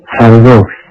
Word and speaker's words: Hyrox: 那个读作 Hyrox。